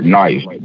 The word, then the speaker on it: knife: Speaking on silent letters, like the word knife. You try to teach kindergarten or maybe up to second grade how to spell knife. They're gonna spell knife N-I-F-E, but you tell them it's wrong because there's a K in there.